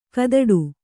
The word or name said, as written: ಕದಡು